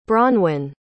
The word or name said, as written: bronwynne